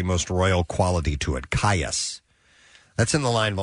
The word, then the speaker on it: Kaius: K-A-I-U-S. How do you pronounce that? K-A-I-U-S. – Caius.